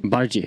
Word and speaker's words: Bardghji: Ehm, men det här, ja det känns som att här, om man kollar på individerna, då nämner jag alltså Rooney Badaji som eh, säger, uttalar hans efternamn. – Bardghji heter han. – Bardghji heter han då tydligen.